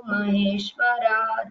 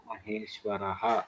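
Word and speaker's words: Maheshwaraha (महेश्वरः): Tasmai Shri See, you should practice the pronunciation properly. Gurur Brahma, Brahma, Gurur Vishnuhu, Gurur Devo Maheshwaraha. So there are subtle improvements which can be made in your pronunciation.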